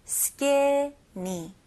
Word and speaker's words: skinny: 여기서는 skinny 이음절입니다. 그래서 skinny가 아니에요. 이거는 외래어고요. 영어로 확실하게 발음하실 거면 skinny. skinny.